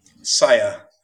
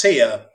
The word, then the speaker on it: Sia: Saya. Well, it's pronounced Sia, but I didn't want to confuse it with legendary Australian vocalist Sia. – Okay. – That's how it's spelled. S-I-A. – How's it spelled? – S-I-A.